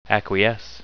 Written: acquiesce

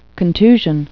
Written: contusion

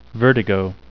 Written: vertigo

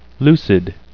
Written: lucid

